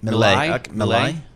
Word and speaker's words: Milei: Milei.